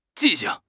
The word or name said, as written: 记性